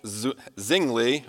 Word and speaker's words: Zwingli: The first one, depending how you want to... pronunciation up there, it's Ulrich Zwingli, or Ulrich Zwingli, which is the more Anglicanized version.